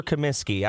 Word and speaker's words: Comiskey: Do we go Kaminsky or Comiskey? I always butcher it. – Comiskey.